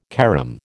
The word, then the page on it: carom: American English